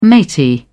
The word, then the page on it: matey: British English